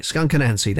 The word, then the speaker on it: Skunk Anansie: Not everybody can say the name right. Skunk Anansie, that's how you say the band.